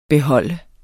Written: beholder

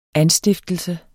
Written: anstiftelse

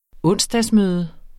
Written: onsdag

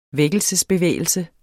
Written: vækkelsesbevægelse

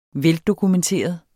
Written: Veldokumenteret

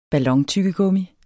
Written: BALLONTYGGEGUMMIET

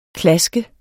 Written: klask

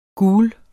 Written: GULERE

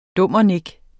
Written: dummernik

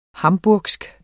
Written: hamburgsk

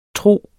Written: tro

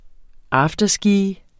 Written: afterski

